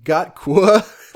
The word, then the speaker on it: GOTQ: Anyway, my pick for the game of the quarter, or GOTQ, pronounced Gotqua.